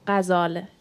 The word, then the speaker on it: Ghazal: ska jag uttala mitt namn rätt på persiska så heter jag Ghazal Zekavat.